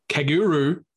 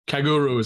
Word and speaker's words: Kagerou: because the evil Kaguru, or that's how I pronounce it, right? Kaguru? – I can't remember, but sure, let's go. – K-A-G-E-R-O-U. Kaguru is how I'd pronounce it.